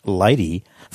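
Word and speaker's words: LADEE: Among a select few allowed to work during the US government shutdown, controllers for NASA's Lunar Atmosphere and Dust Environment Explorer, pronounced LADY, fired the spacecraft's engines on Sunday morning, October the 6th, slowing it enough to be captured by lunar gravity.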